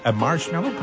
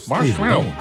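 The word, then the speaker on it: Marshmello: La preuve que vous êtes au meilleur de la musique, c'est Marshmallow. Comme ça, on le prononce. – Ouais, tu l'as bien. – Marshmallow.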